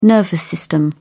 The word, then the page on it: nervous system: British English